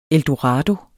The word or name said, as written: eldorado